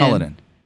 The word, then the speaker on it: Cullinan: And then finally, a 2020 Rolls-Royce Cullinan. Am I saying that right as well? – I think it's... Yeah, I think you are. I think Cullinan. It's Cullinan.